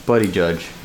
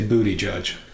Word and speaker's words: Buttigieg: I still don't believe that his last name is pronounced Buttigieg. – Palpatine? – Mm-hmm. The very same. – Buddy judge. You make that joke every time. – Do I? – Well, actually, you say booty judge.